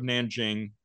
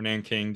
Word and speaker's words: Nanjing: Like, yeah, well, there's the famous one, which is the rape of Nanjing, or Nanking, depending on how you pronounce it.